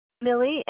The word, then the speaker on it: Millie: My name is Millie Harris. – Can you spell it for me, please? – Yeah, Millie, M-I-L-L-I-E, and the last name is Harris, H-A-R-R-I-S.